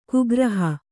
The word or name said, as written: ಕುಗ್ರಹ